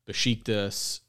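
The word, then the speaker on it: Beşiktaş: Uh, this article puts a lot of emphasis on his previous international experience with Besiktas and I think PSV um, Eindhoven. Nice pronunciation with the sh in Besiktas.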